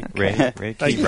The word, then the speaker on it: Reiki: I always said Reiki. Have I been saying it wrong all the time, or is this just another example of you making up your own weird pronunciation? I think it is Reiki, actually. – Reiki. – R-A-Y-K-E-Y.